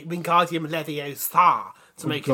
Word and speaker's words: Wingardium Leviosa: It's pronounced Wingardium Leviosa.